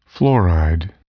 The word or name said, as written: fluoride